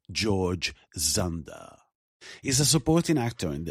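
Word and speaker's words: Dzundza: His name is George Zunda. It's pronounced Zunda.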